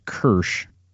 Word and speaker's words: Kirsch: But I may have even written this down somewhere at some point, but I think it's pronounced Kirsch, K-I-R-S-C-H, Kirsch Hunting Bloodhound XTN.